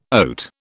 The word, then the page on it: oat: British English